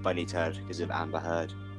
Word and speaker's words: Heard: Because her name is Amber Heard and Heard rhymes with Turd.